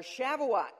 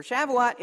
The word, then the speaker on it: Shavuot: Now, there is a Jewish feast called the Feast of Weeks, or Shavuot, if I'm saying that right.